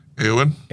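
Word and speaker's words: Éowyn: With Mirando Otto returning to voice Ewan. How do you say that? – Eowyn. – Eowyn.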